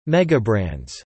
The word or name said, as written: megabrands